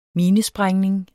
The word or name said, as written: minesprængning